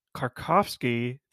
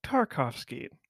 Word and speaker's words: Tarkovsky: I was pronouncing Andrei Tarkovsky the entire episode and not saying Tarkovsky.